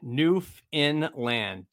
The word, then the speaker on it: Newfoundland: Angie, Newfoundland, Newfoundland. Don't pronounce found.